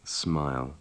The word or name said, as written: smile